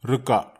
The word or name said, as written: reka